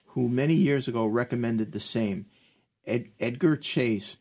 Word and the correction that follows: Cayce: pronounced Casey.